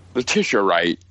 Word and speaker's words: Letitia Wright: Letitia Wright.